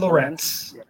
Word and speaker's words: Lorenz: Lorenz, is that how you pronounce it? – Yes, Stephen Lorenz.